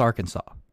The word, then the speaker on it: Arkansas: I mean, it's definitely not Arkansas, it's pronounced Arkansas.